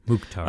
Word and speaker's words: Mukhtar: 75th minute, Hany Mukhtar, Mukhtar, I think is how you say it. – Mukhtar.